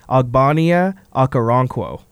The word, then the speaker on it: Ogbonnia Okoronkwo: And that is, now correct me if I say this wrong, this guy has a weird name, Ogbonnia Okoronkwo. – That sounds right for me.